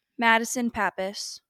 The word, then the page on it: Madison Pappas: female